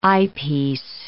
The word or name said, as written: eyepiece